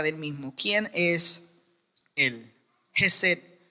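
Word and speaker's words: Gesed (חֶסֶד): Y algunos que eh, hablan correctamente hebreo, eh, la pronunciación sería Gesed.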